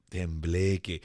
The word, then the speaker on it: tembleque: Tembleque.